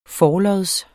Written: forlods

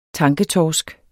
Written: tanketorsk

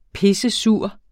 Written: pissesur

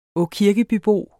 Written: åkirkebybo

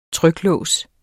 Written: tryklås

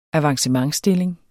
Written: avancementsstilling

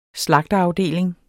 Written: slagterafdeling

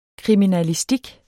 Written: kriminalistik

